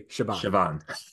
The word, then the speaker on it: Siobhan: One, two, three, Siobhan.